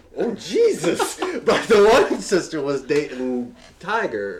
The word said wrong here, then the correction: Tyga: or the rapper. – Okay, however you pronounce that. Tyga.